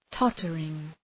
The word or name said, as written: tottering